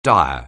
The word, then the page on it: dire: anglais